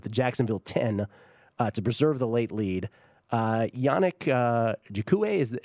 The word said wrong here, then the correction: Ngakoue: am I pronouncing that correctly? Yannick Ngakoue.